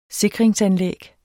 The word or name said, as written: sikringsanlæg